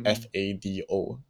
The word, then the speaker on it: fado: It's spelled as FADO, but pronounced as fado in Portuguese.